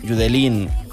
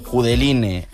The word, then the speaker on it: Judeline: Y bueno, la historia de por lo que estamos escuchando esto es que Judelín, Judeline, Judeline, como la queramos llamar, porque todos los nombres son válidos.